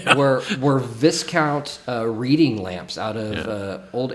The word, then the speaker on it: Viscount: Because it's spelled V-I-S-C-O. – Yeah, and you Viscount. Would, like, for us as Americans, we would pronounce it that way, but because it's British, it's pronounced differently, and it's Viscount.